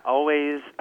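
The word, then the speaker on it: Alvvays: Always, pronounced Always, but it's spelled with two V's, is a Toronto guitar-based pop group.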